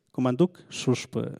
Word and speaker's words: Kumantuk Xuxpe: Bueno, K-U-M-A-N-T-U-K X-U-X-P-E con diéresis, Comanduk, Shushp.